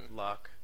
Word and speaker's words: Locke: Locke. Dude, it's Locke. – Fuck your couch. – Loki. – Fucking damn, it's Locke. – Pretty sure it's supposed to be pronounced Loki. – L-O-C-K-E is named after a philosopher.